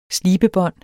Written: slibebånd

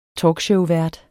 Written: talkshowvært

